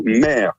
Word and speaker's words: Meyre: C'est le château Mère. Ça s'écrit M-E-Y-R-E, si vous voulez voir ce lieu qui est magnifique.